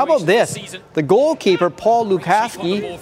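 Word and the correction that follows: Gulácsi: Actually, I'll say Gulacki, because that's the correct way to say it.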